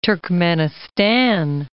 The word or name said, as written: Turkmenistan